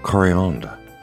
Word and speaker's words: coriandre: Coriander in French is pronounced coriandre and spelt very similar to coriander in English. In French, it's C-O-R-I-A-N-D-R-E.